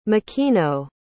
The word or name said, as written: mikino